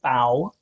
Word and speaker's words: FAO: It tells us that in 1945, the work of the FAO, the FAO, rhymes with cow.